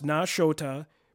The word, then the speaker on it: Nashota: And a lot of people don't pronounce this one right. This is kind of one I've seen get messed up often. – Yeah, Nashota. – But it's actually pronounced Nashota, but actually comes from the Potawatomi word and is Nishota, which means two or twin rivers due to the nearby waterways in the area.